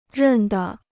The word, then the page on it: 认得: rèn de